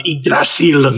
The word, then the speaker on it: Yggdrasil: The relief of Yggdrasil. – Yes. – That's how you got to say it like he did. Very, very forceful.